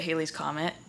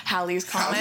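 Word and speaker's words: Halley's Comet: I know that I've always pronounced it Haley's Comet. But I've Googled it. I Googled it a bunch of times to see how it's actually pronounced, because yeah. I didn't want to get myself excited and then hear her say, like, Hallie's Comet.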